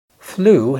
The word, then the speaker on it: flew: Flew هي الماضي من fly، وخد بالك من النطق flew، كأنك بتنطق كلمة blue أزرق على نفس الوزن.